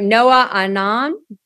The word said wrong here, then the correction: Noaaon: the brand name? – Noah Aeon. – Noah Aeon. So N-O-A-A-O-N.